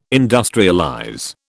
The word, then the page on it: industrialize: British English